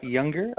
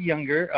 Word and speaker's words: Juenger: My name is Scott Younger. Kind of interesting last name. It's German, so it's not spelled the way it sounds. It's actually spelled J-U-E-N-G-E-R.